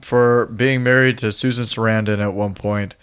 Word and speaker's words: Sarandon: But so he calls Jerry, who is our vampire played by Chris Sardonis. – Chris Sarandon. – Sarandon.